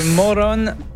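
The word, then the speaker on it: Morón: Où c'est le Moron Aéroport. – Ah, vrai prononciation. – Un petit accent, là. – Ouais, à l'espagnol, là.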